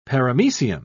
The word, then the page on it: paramecium: British English